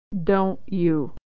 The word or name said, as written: don't you